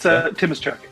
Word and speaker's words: Timoshuk: It's Timoshuk, but you're like 98% of the way there.